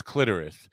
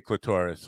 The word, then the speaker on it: clitoris: I know it's clitoris, and I always say clitoris. I don't know because it rhymes with lavoris, which you use after licking the clitoris.